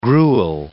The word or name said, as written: gruel